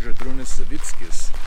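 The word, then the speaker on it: Zydrūnas Savickas: And the actual way you pronounce his name is Zydrunas Savickas. – Yes. – And it's just weird because it doesn't look like that at all.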